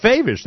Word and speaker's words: Faivish: It's Faivish, there you go.